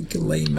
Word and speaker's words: Ghislaine: I think that's the first time I've pronounced it correctly. Ghislaine Maxwell.